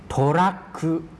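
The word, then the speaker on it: โ ทรัก คุ (トラック): โทรักคุโทรักคุครับลองดูที่โรมันจินะครับตัวแรกออกเสียงสั้นนะครับเสียงแรกออกเสียงสั้นนะครับโทเสียงที่สองจะออกเสียงยาวนะ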